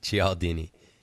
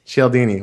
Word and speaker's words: Cialdini: Cialdini. – Cialdini.